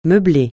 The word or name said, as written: meubler